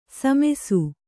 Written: ಸಮಸು

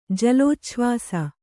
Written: ಜಲೋಚ್ಛ್ವಾಸ

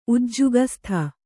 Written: ಉಜ್ಜುಗಸ್ಥ

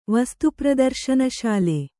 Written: ವಸ್ತುಪ್ರದರ್ಶನಶಾಲೆ

